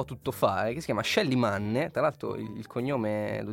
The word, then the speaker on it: Manne: Molti ascoltatori hanno curiosità linguistiche e quindi il cognome è di origine austriaca, quindi lo pronunceremo Manne. Non abbiamo idea di come lo pronunciano gli americani, ma ci va bene così.